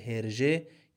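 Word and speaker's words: Hergé: واژه هرژه که قبلا گفتیم تلفظ دو حرف آرجی هست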